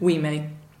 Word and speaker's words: Ouimet: Who David Ouimet. How do you spell that? Think French.